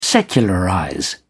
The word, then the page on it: secularize: British English